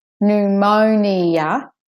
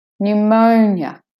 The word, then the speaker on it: pneumonia: So it's not pneumonia, but pneumonia, pneumonia. The O is the stress, main stressed syllable. Pneumonia. N, de-stressed. Mo, stressed. Nya, de-stressed. Pneumonia.